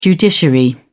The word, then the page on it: judiciary: British English